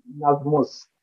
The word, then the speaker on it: Nazmus: Yes, it is Nazmus.